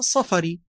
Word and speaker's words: سفاري: ولو كنت ترغب في معرفة النطق الغربي للفظة سفاري، فلتتخيل أنها صفري بفتح الصاد والفاء.